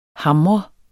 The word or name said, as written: hamrende